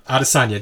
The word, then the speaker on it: Adesanya: I'm gonna I'm gonna butcher the name. Who Israel Adesanya. – Adesanya, thank you.